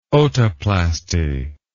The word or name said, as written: otoplasty